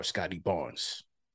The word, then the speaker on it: Scottie Barnes: What's your thoughts on this Raptors core, Scottie Barnes? – I think you said it right.